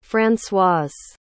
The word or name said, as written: françoise